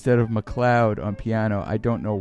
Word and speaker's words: McLeod: After that, we heard El Nutto, the title track from the album El Nutto by Terry Gibbs with Alice McLeod, this time spelled like McLeod, like the TV show with Dennis Weaver. Except that was many years before. MCL, like Mick Cloud, like a cloud in the sky.